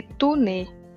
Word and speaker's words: Taunay: Se escreve Taunay com Y, mas se lê Tunê, tá bom?